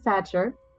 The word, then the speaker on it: Sachar: I always say Cassandra O'Sullivan Sachar, long in name, short in stature, since I am short and nobody ever knows how to pronounce my last name.